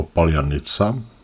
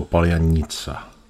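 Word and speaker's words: paljanica (паляниця): Ukrajinec to vysloví jako paljanica, Rus jako paljanica.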